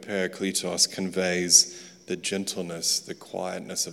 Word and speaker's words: parakletos: So you're going to get a sort of Texan Australian pronunciation of a Greek word, but parakletos.